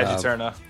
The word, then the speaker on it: Juturna: But at the time, this was like Circa was on, how do you say, is it Juturna? Is that how you pronounce it? – Yeah, Juturna.